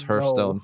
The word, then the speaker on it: Hearthstone: I thought it was Hearthstone for a long time. But apparently it's Hearthstone. We asked a fellow nerd, and it is Hearthstone.